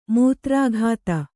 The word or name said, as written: ಮೂತ್ರಾಘಾತ